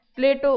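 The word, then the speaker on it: Plato: प्लेटो उच्चारण अंग्रेजी के अनुसार है। इस शब्द का यूनानी उच्चारण प्लाटोन है, जो अरबी में अफलातून के रूप में प्रचलित है। इस पुस्तक में अंग्रेजी का ही उच्चारण रखा गया है, क्योंकि यूनानी उच्चारण रखने से पाठकों को अनावश्यक भ्रम होता है।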